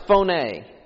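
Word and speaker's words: phoné (φωνή): Now the Greek word that was used here for voice is pronounced phoné.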